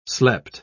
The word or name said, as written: slept